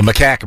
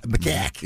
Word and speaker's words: macaque: What is it? It's macaque. – The macaque monkey, yeah. – Okay, I got macaque. Yeah. Okay. I got this. – Rhymes with backpack.